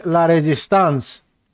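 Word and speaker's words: La Résistance: Προφέρεται La Resistance. Εγώ τι είπα, ρε φίλε, La Resistance.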